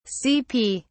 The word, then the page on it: cp: British English